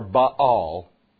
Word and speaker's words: Baal: That same Phoenician alliance that opened the door to great trading opened a wide door to the worship of Baal, or Baal, is how it's pronounced, in Israel.